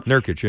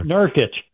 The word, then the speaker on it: Nurkić: They have a center over there named Yusuf Nurkić, and I'm probably pronouncing that wrong. – Nurkić, yeah. – Nurkić.